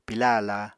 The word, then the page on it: pilá aláa: Apatani